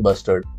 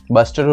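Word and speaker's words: bustard: बस्टर्ड और great Indian बस्टर्ड को बना सकते थे बट इसलिए नहीं बनाया क्योंकि उन्हें लगा कि लोग मिस प्रोनाउंस करेंगे ग्रेट इंडियन बस्टर्ड को बस्टर्ड और क्या बास्टर्ड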